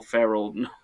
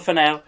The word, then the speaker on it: Ferrell: But it was in that wave of these Judd Apatow type comedies with who I realized I kept saying Will Ferrell, like I'm saying Emerald Fennell, but it's Will Ferrell.